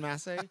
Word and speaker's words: Mase: I've been called worse things, but Desmond Mase, you are on.